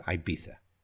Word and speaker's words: Ibiza: My Spanish is not so perfect, but the English usually pronounce it Ibiza. Ibiza. Yeah? Even though it's spelled I-B-I-Z-A, we have that TH sound at the end, Ibiza.